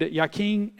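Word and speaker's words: Jachin: They were named Yakin and Boaz. You will see Yakin there. It's spelled with a J, but it's pronounced with a Y because it comes from the Hebrew.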